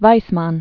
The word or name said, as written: Weismann